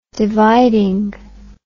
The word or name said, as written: dividing